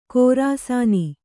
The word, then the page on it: ಖೋರಾಸಾನಿ: khōrāsāni